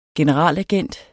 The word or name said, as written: generalagent